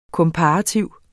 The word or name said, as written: komparativ